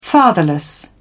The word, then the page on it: fatherless: British English